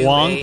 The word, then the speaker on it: Huang: Ray Hong, H-U-A-N-G, for Sarah. Ray Hong. – Okay. This is... Is that how it's pronounced? How's it spelled? – Huang? – Is it H-U-A? – Huang, probably? Yeah, H-U-A. – It's Huang. Yeah, it's basically...